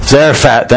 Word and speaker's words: Zarephath: Zarephath, thank you.